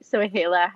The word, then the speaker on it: Soheila: It's actually Soheila Hakimi.